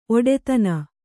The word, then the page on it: ಒಡತಣ: oḍataṇa